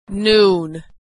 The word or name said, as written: noon